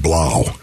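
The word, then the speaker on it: Blough: David Blau. This is a guy that, again, pardon? – Rhymes with cow. – Rhymes with cow. It showed the cow offense.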